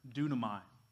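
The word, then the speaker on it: dunamai (δύναμις): See, this word in the Greek is the word dunamai. Kind of sounds like the word for dynamite, right? Dunamai, dynamite.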